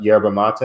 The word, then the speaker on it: yerba mate: I, for one, am one of those people who do not know what yerba mate. Am I saying that correctly? – Yes, you are.